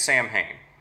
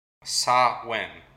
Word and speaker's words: Samhain: And also, if you're just going to pronounce it as it looks like it's spelled, it's Samhain. – Ah, wait, can you say how you correctly say it again? – Samhain. – Samhain. – Yeah, sort of like if you're going to spell it phonetically, it'd be S-A-H-W-I-N.